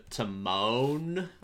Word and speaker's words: Timon: I also say to moon instead of to moan.